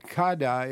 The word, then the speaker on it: Kade: And if people want to learn some more, Kada, it's spelled K-A-D-E.